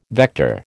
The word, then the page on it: vector: American English